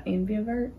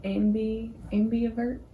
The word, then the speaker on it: ambivert: It's called an ambivert. – Mm-hmm. How do you say that? – Ambivert. – Is it an ambulance? Ambivert? – Yeah.